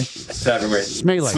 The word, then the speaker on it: Smeele: Smeele.